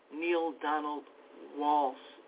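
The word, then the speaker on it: Neale Donald Walsch: Neil Donald Walsh, and his name is spelled N-E-A-L-E. Donald, of course, D-O-N-A-L-D, Walsh, W-A-L, S-C-H. Neil Donald Walsh.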